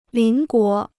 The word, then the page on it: 邻国: lín guó